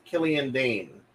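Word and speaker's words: Killian Dain: And your wild card is Killian Dane. – Yeah. – If I'm pronouncing that correctly. – That is correct.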